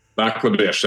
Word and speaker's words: Lac La Biche: And did I get the pronunciation right on the place you live, Lac La Biche? – Lac La Biche, that's correct.